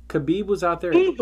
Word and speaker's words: Khabib: Khabib with a B. Khabib.